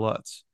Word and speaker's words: Lutz: Will Lutz in the NFL is pronounced Lutz with the same spelling, so I think we go Lutz.